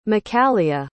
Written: makalia

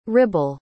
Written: Ribble

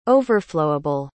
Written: overflowable

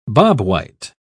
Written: bobwhite

